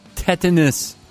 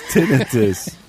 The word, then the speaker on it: tinnitus: Yeah, tetanus. – Not tetanus, tinnitus. Tinnitus. Some people call it tinnitus, but it's tinnitus.